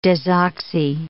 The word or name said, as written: desoxy